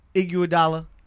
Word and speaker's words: Iguodala: Iguodala. – Is that a phonetic way of saying it? – Yeah, that's how you say it.